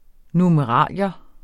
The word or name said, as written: NUMERALIER